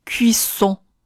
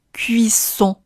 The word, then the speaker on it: cuisson: cuisson cuisson 这个词的另外一个发音难点，就是在发完一这个元音之后，要迅速的回到撅嘴音，发出接下来的闭环元音。 o on son cuisson cuisson，你需要多多练习，才能把口腔肌肉练得越来越灵活。